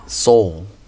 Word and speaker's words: Seoul: 소울. – 왜 이렇게 발음할까요, 미국 사람들은? 서울 그러지.